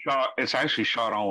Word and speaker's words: Charron: Charron, basically, I guess. It's actually Charron when you say it in French.